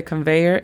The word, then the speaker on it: conveyor: La pronunciación fonética de conveyor es K-A-N-V o V-E-Y-R. Conveyor. – Conveyor belt. – Yes, belt. Es B-E-L-T.